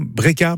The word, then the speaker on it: Breca: Elle s'appelle Breca. Je sais pas si je prononce bien. Breca. – Oui, c'est comme ça. – Breca.